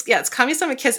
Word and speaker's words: Kamisama Kiss: Yeah, it's Kamisama Kiss. And I think it's like, in like the Japanese is Kamisama Hajimashita.